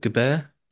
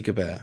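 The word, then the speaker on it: Gabert: Some of the figures that he had announced was Jazzy Gilbert, Jazzy pronounced Gilbert Gebert, Jazzy Gebert, I believe it was pronounced.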